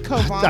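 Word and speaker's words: Cauvin: It's pronounced Covan.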